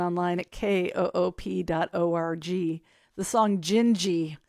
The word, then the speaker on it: Dindi: D-I-N-D-I is how it is spelled, but it is pronounced Gingy because it's Portuguese.